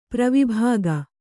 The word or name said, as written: ಪೂರ್ವಭಾಗ